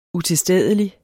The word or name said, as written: utilstedelig